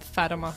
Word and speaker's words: Fatima: I get a lot of different pronunciations, but usually Fatima.